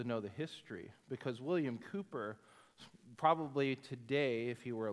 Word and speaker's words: Cowper: It's in the hymnal, it looks like Cowper, but it's pronounced Cooper.